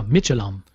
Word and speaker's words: Midtjylland: Midland? – Midland? – Midland? Midtjylland. – They're Danish. – Yeah, yeah, there you go. Midtjylland.